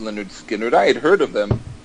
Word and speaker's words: Lynyrd Skynyrd: And so I heard Lynyrd Skynyrd's album pronounced Lynyrd Skynyrd.